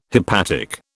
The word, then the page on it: hepatic: British English